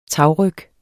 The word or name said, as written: tagryg